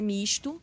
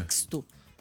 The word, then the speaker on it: Misto: É Misto, mas aí o Thiago Muniz fez questão de escrever Misto com S para saber como é que pronuncia. – Tá? Flores da Cunha. – Não é Mixto, no Misto.